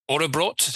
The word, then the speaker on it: Årabrot: Hi from Ghost Cult, and we're delighted to be joined by Kjetil and Karin from Årabrot.